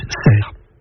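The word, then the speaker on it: Sercq: Cela montre que la prononciation originelle était Cerque, mais que dès cette époque, la consonne finale n'a plus été articulée et que l'on a dit ensuite Cerre. C'est la même chose dans des noms communs comme Port ou Clair. Ils s'écrivent avec un C, mais il est muet. J'ajoute que la forme anglaise du nom de l'île s'écrit avec un A. Mais ce n'est pas pour cela qu'il faut dire Sark. Ce serait ignorer la prononciation locale et la prononciation française.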